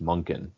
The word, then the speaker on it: Monken: I think it is pronounced Monken too. – Monken. – With an N.